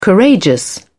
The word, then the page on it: courageous: British English